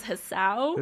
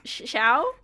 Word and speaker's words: Hsiao: Like I actually didn't even say her name right when I was first looking. I thought it was Hsiao. – Hsiao. – But I know now it's Hsiao.